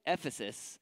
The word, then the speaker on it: Ephesus: How do you say it? Ephesus. Say it, Ephesus. Thank you.